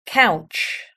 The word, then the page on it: couch: British English